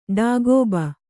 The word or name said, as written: ಡಾಗೋಬ